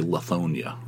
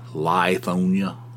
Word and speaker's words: Lithonia: You would think it would be Lithonia, right? I mean, that's the way it's spelled. But it's pronounced Lithonia, you know.